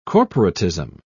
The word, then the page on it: corporatism: British English